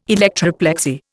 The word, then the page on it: electroplexy: Indian